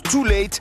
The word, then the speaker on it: Too Late: Son nom s'écrit de manière stylisée avec un U et un 8, mais ça se prononce Too Late.